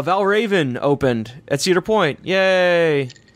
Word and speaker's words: Valravn: There's no extra R, though. It's just one R. – Valravn. – Yeah, Valravn.